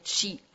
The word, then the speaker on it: Qi: Aber dann gibt es Prana aus dem Hinduismus oder Energie, sagt man, oder Ki oder Chi oder schreibt sich auch mit Qi, wird Chi ausgesprochen.